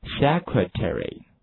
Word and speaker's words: secretary: 那么在美国英语中，请大家注意了，这个 A 是发音的，就是 ary 这个 A 发的是 ad 音，所以说在美国英语中它是读成 secretary。